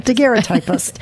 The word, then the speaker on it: daguerreotypist: It's illustrated by a daguerreotypist. Is that how you say it? – Daguerreotypist. – Yeah, exactly. Jerry Spagnoli.